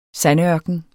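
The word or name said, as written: sandørken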